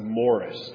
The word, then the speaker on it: Maurice: Yeah, there was a famous pastor in Scotland, and his name was spelled M-A-U-R-I-C-E. And growing up, I would call him Maurice and not Morris.